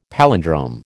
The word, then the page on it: palindrome: American English